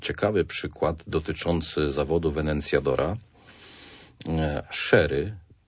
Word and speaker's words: sherry: Ja tutaj zwracam uwagę na wymowę, bo niektórzy będą myśleć, że sherry to jest wiśniówka. Absolutnie nie chodzi o wiśniówkę, bo tam mamy literę C z przodu i wtedy musielibyśmy mówić cherry.